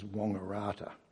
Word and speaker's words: Wangaratta: Okay, it's a town called Wangaratta, which you would pronounce as Wangaratta.